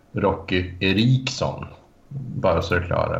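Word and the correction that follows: Roky: För man, man uttalar Rocky som Rookie Eriksson.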